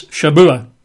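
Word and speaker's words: Shabuah (שבוע): It's not weeks. It's Shabuah. Oh. Right? Shabuah. In English, it's a Hebrew word, obviously. In English, we'd spell that as S-H-A-B-U-A.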